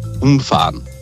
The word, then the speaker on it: umfahren: Und zwar das Wort umfahren. Mhm. Je nach Betonung heißt es entweder, ich fahre rundum etwas oder ich fahre gerade drüber.